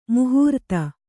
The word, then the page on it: ಮಹಾರಥ: mahāratha